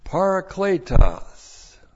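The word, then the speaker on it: parakletos (παράκλητος): will give you another parakletos." Looks like that in the Greek, that's P-A-R-A-K-L, long E-T-O-S, parakletos.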